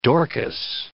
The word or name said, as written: Dorcas